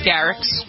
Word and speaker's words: Garrix: Is that how you pronounce his name? – I think it's Garrix. – Garrix? – Yeah.